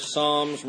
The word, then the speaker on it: Psalms: If not, you can go to your table of contents and look under Psalms. Remember, the P is silent.